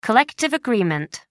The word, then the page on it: collective agreement: American English